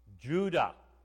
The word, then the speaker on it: Judah: That's the Hebrew, right to left, Huda. We pronounce the J's. Most languages don't have a J. We pronounce the J. It is Judah.